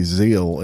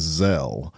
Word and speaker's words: Zelle: I say I used to say Zeal, and then somebody told me it was Zell.